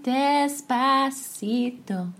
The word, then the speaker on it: Despacito: Despacito? – Despacito. – Oh, I said it right.